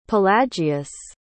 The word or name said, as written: pelagius